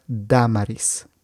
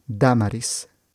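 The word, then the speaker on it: Dámaris: Entonces, tienes diferentes proyectos y también aprendí algo nuevo, que tu nombre se pronuncia Damaris. Damaris. – Sí, mi nombre se pronuncia Damaris, se escribe también con acento o la tilde en la primera A, y así es como se pronuncia mi nombre.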